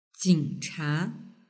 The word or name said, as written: jingcha